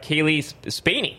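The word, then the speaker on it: Cailee Spaeny: the Kaylee Spaney. Am I pronouncing that right, Victor? – Yes.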